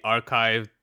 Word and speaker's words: arXiv: Basically, there was a paper that was posted to the archive, spelled ArcSiv, but pronounced archive for some reason.